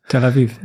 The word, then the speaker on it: Tel Awiw: Tel Awiw.